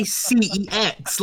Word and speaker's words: CeX: The thing is, right, what bugged me, everyone says CEX, and then in their own adverts, they started saying sex.